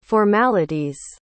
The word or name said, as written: formalities